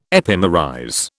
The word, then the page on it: epimerize: British English